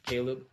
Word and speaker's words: C47EB: It's Caleb, but spelled with a 4 and a 7 instead of an A and an L. That's C47EB.